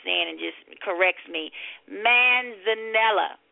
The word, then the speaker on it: manzanilla: I got a pimento stuffed Spanish manzanilla olives. I know I didn't put the emphasis on the right word with that manzanilla. Manzanilla.